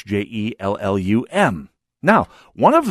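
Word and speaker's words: Hjellum: On the other hand, the other fellow, a, a lawyer in town of some repute, uh, always pronounced the name Jellum.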